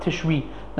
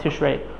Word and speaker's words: Tishri: I heard the Prima Gadam in the beginning of Sefer Netrikin says it's supposed to be pronounced Tishri, not Tishrei.